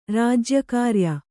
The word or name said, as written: ರಾಜ್ಯಕಾರಿಯ